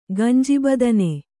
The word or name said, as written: ಗಂಜಿಬದನೆ